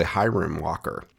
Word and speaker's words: Hiram Walker: In 1989, Herman Walker, I've been mispronouncing this the whole time. It's actually Hiram Walker, so my apologies.